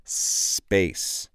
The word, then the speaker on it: space: Remember how we pronounce these words in English that begin with an S. Space. Now, the way to avoid pronouncing, for example, an E before this word, which doesn't exist, let's really emphasize or stress the S.